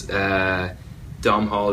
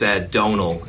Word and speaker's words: Domhnall: But it's Donal is how I pronounce my name, which is like tonal but with a D instead of a T, and the M is just there to confuse Americans.